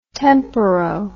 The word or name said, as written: temporo